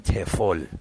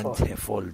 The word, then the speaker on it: TEFL: این پیش نیازش بیشتر بهش میگن تفل، به این میگن تفول تو ایرانی ها خیلی اینجوری تلفظش میکنن.